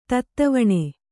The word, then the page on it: ತತ್ತವಣೆ: tattavaṇe